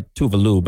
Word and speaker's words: Tove Lo: Then you got Tove Lo. Technically, she goes by Tuvalu, but no one pronounces it right.